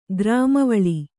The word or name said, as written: ಕರ್ಮವಲೆ